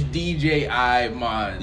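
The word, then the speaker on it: Djimon: I'm sure I butchered it. – It's DJI-mon.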